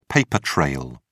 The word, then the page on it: paper trail: British English